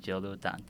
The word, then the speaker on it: Jorudan: 它其实你就念 Jorudan J O R U D A N。